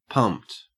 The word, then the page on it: pumped: Australian English